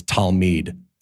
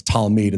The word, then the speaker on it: Talmid: or one of the Hebrew words for disciple is Talmid. And am I saying that right, Matt? You think so?